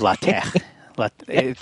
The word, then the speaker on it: LaTeX: LaTeX. Technically, it's tech is really supposed to be tech, but nobody says it that way.